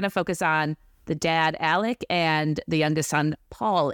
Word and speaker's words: Alex: And then we have Richard Alexander Murdaugh, known as Alex, but for some reason everyone pronounces it Alec.